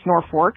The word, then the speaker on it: Norfolk: First, we have Norfolk, which is spelled Norfolk, but pronounced Norfolk. And if you say it as spelled, people from there will get upset.